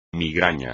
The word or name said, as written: migraña